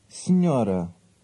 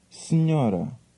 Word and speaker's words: Senhora: Senhora. Senhora. – The N sound in that word is similar to the N in canyon. Be careful with that pronunciation.